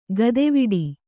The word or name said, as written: ಗತಿವಡೆ